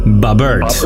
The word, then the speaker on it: Bobbert: I'm currently pickpocketing Bobberita? – Bobbert. – Bobbert. – Bobbert. With a silent B.